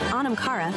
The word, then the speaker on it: Anam Cara: In Irish, Anam Cara, pronounced Anam Cara,